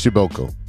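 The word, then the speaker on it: Przybyłko: because it's spelled Przybylko, but it's pronounced Schibelko.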